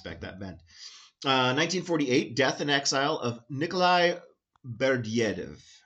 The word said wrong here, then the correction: Berdyaev: I said that wrong. Berdyaev. just Berdyaev, almost like it might be French, a Russian Orthodox political theorist and associate of Jacques Martin and his circle.